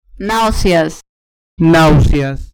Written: nauseas

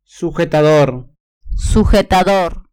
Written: sujetador

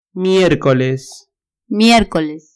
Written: miercoles

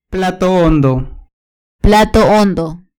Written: plato hondo

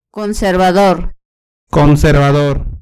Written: conservador

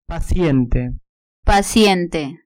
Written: paciente

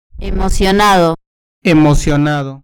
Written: emocionado

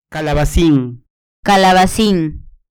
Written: calabacin

